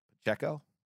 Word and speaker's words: Pacheco: That's a tough name for me. Pacheco. He's been good. – And said it right.